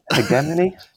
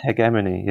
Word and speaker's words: hegemony: Now, this last word, hegemony. I've never heard it said out loud. I don't know how you say it. – Hegemony? Hegemony, yeah.